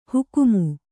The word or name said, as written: ಹುಕುಮು